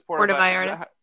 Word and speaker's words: Puerto Vallarta: So there's been a big uproar in the gay community because Porta, off that, wherever that place is. – Puerto Vallarta. – Somewhere in Puerto Rico. – That's what it is. – Puerto Vallarta. – Yeah, that's how you say it.